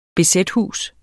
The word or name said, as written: bz-hus